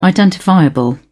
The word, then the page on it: identifiable: British English